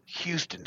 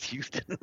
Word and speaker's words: Houston: By the way, it's pronounced Houston Street. It's Houston.